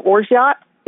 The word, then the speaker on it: orgeat: And then you're going to add a quarter ounce of your orgeat. If you don't have orgeat or you can't find orgeat, and that's spelled O-R-G-E-A-T, or like orgeat is what it might look like.